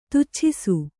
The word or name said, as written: ತುಚ್ಛಿಸು